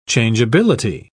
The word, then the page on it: changeability: British English